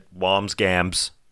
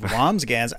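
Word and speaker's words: Wambsgans: Yeah, I said Wamsgams. It's Wamsgans. – It's Wamsburger. Oh, is it? Okay. – No, there's an N or an M. It's hard to say. It's just two syllables. But Wamsgans.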